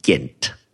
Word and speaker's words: Ghent: it's obviously not spelt G-E-N-D, but the way they're pronouncing it sounds like we need to put an H in there, so let's call it Ghent. Anyway, that's probably us But then the H is silent.